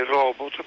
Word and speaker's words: robot: Quindi molto spesso si sente parlare di robot invece che di robot, ma la pronuncia eh, corretta dovrebbe essere robot perché il francese in realtà non c'entra nulla.